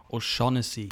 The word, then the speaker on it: O'Shaughnessy: Ich habe das Glück, einen irischen Arbeitskollegen zu haben und er sagte zu mir, O'Shaughnessy. Daniel O'Shaughnessy, ja, das klingt schon irgendwie geil.